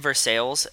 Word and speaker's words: Versailles: By the way, I just said Versailles, and I know that sounds weird, but that's because in Pennsylvania we have a town called it's spelled Versailles, but called Versailles. So when I read that word, I hear Versailles.